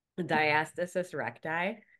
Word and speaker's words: diastasis recti: And if you have DR, diastasis retractus, I never say that. How do you say it? – Diastasis recti.